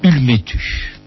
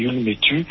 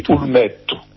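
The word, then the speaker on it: Ulmetu: Ulmétu. – Alors en français on dit Ulmétu, mais en Corse le U se prononce U, donc on dit Ulmétu.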